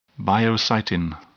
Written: biocytin

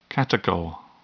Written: catechol

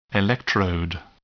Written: electrode

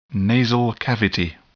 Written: nasal cavity

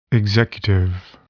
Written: executive

